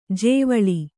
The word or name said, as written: ಜೇವಳಿ